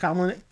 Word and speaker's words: columnist: A gossip columnist? Nope. – A gossip columnist? Columnist? How do you pronounce that word? – Columnist.